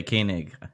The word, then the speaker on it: Koenig: Koenig? – Yeah, Koenig.